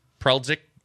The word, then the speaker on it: Preldzik: Preldzik. P-R-E-L-D-Z-I-K. – It was three syllables the first time you said it.